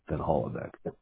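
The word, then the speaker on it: Van Hollebeke: Van Hollebeck is my last name. It's spelled V as in Victor, A, N as in November, space, capital H, as in Harry, O-L-L-E-B, as in Bravo, E-K-E, Van Hollebeck. – Got it. E-B-E-K-E? – Yes, sir.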